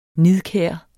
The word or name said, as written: nidkær